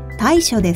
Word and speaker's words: 大暑: 大きいに暑いと書いて大暑です。